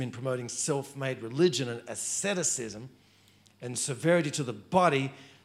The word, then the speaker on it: asceticism: And the last one, excessive asceticism. Oh, I said that right. Excessive asceticism.